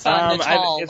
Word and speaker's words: Natal: Is it Natal or Natal? – It's Natal, last I heard, as in the Brazilian city.